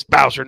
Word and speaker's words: Bowser: Every time I go to Springfield and I see them at their booth, I always say, "Hey, can you please make this Bowser not sponsored?" – I was going to say, does it rhyme with Flouser? – Yeah.